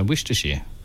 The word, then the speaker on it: Worcestershire: No, Worcestershire. You might pronounce it Worcester.